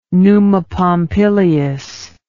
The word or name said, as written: Numa Pompilius